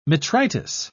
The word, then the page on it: metritis: British English